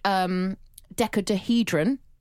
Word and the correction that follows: dodecahedron: I think I've said that wrong. Decahedron? – Dodecahedron? – Dodecahedron. – Yeah.